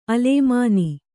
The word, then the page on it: ಅಲೇಮಾನಿ: alēmāni